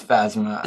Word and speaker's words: Phasma: Pashma, isn't it? – Phasma. – It's Phasma. – It's Phasma? – Yeah, the P is silent. But yeah, PH says fuh.